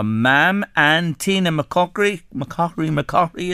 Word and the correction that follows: McClockery: Linda, correct me, will you, on the pr- pronunciation. McCockery, is that right? – McClockery. McClo- okay, there's an L in it, is there? – Yeah. McClockery.